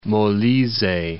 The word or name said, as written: Molise